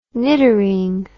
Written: niddering

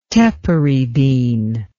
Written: tepary bean

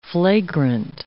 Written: flagrant

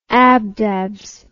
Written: abdabs

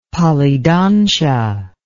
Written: polydontia

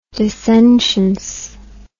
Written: dissentience

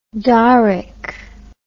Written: dorrick

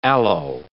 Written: aloe

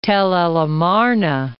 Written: Tel el Amarna

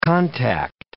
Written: contact

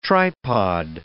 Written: tripod

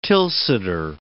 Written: Tilsiter

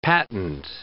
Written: patent

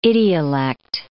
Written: idiolect

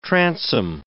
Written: transom

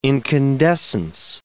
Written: incandescence

